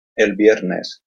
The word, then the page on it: el viernes: Spanish